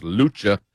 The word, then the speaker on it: Luce: It's pronounced Luce. It's spelled Luce, L-U-C-E.